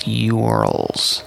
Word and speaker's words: YOURLS: Is it pronounced URLs? It's spelled YOURLS, URLs.